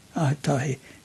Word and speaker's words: Aotahi: You've got Aotahi. – Aotahi. – Right. Yes.